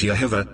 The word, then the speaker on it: Jehovah: Of all the pronunciations, Yahweh and Jehovah, correctly pronounced as Yehovah, are the most accepted, and really, all the variations cling to either one, coming from the root, Yah.